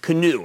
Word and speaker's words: Canoo: I'm talking about Canoo, or I'm going to spell it, C-A-N-O-O.